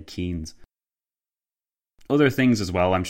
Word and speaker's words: Keynes: So I said Keynes rather than Keynes several times.